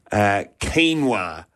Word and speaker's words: quinoa: Quinoa.